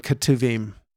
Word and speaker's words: Ketuvim: And in Hebrew, that's Torah, Navim, and Ketuvim.